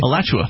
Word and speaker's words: Alachua: Alachua, A-L-A-C-H-U-A? – Alachua.